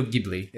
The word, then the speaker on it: Ghibli: Yeah, Studio Ghibli is how most people will say it.